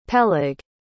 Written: peleg

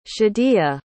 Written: shadiya

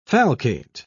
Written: falcate

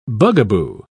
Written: bugaboo

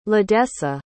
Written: ladessa

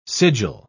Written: sigil

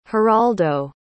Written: geraldo

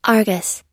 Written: argus

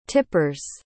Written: tipper's

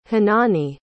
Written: honani

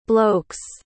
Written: blokes

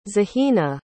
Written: zahina

